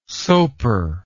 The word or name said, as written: sopor